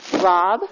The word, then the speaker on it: Raub: Yes, my name's Beth Robb. I'm with the Women's Shelter Program, San Luis. Oh, Robb is spelled R-A-U-B.